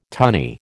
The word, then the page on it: tunny: American English